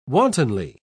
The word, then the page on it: wantonly: British English